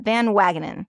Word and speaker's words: Van Wagenen: and I'm Van Wagonen. – Wagenen. That sounds. I'll, I'll go with that. Van Wagenen,